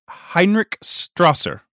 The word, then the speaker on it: Heinrich Strasser: Major Heinrich Strasser.